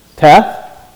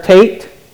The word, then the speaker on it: Teth: Sounds like a T, Teth. Sounds like a T, but you pronounce this letter Tate. It's a Tate. It's a Hebrew letter, Tate.